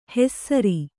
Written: ಹೆಸ್ಸರಿ

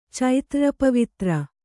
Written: ಚೈತ್ರ್ರಪವಿತ್ರ

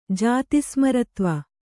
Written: ಜಾತಿಸ್ಮರತ್ವ

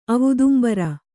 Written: ಅವುದುಂಬರ

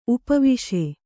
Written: ಉಪವಾಸ

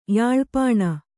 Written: ಯಾಳ್‍ಪಾಣ

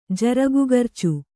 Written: ಜರಗುಗರ್ಚು

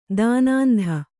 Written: ದಾನಾಂಧ

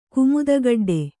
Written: ಕುಮುದಗಡ್ಡೆ

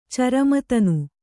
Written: ಚರಮತನು

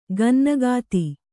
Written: ಗನ್ನಗಾತಿ

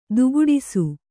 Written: ದುಕ್ಕಡಿಸು

